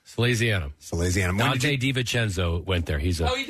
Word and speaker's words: Salesianum: Let's give you a lesson on how to pronounce his high school. – Oh, yes. Salesianum. – No. – Salesianum.